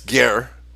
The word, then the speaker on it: ger: and that's ger, okay, as we have been insisting all along that ger g e r, which is the prefix, uh, the first syllable of the word German, a German, mm-hmm.